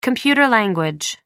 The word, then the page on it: computer language: American English